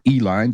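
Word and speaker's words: Uihlein: Tiffany has been supported in the past by GOP mega donors, Richard and Elizabeth Uline, or Eline, depending on how you like to pronounce that, owners of Pleasant Prairie-based Uline Incorporated.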